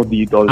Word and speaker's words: Didos: Sì, beh, allora, innanzitutto volevo una piccola precisazione, la pronuncia così è all'americana, però uh-huh. Diciamo noi che siamo italianizzati, diciamo Didos. – Ah, quindi... – Didos, perfetto.